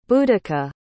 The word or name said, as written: boadicea